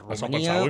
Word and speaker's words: Romania: Exacte, ho pronunciem bé, perquè com que ja sabeu que les nostres tòniques quan tornen àtones se pronuncien ús, és a dir, no hi ha cap pega. Romania, Hongria.